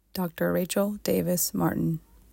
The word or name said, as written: Rachel Davis Martin